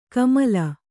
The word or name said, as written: ಕಮಲ